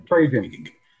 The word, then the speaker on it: Trajan: Yeah, I think in the piece you compare Dave to, I always mispronounce his name, but the Roman Emperor, is it Trajan? I think it's Trajan Langdon who played for Trajan.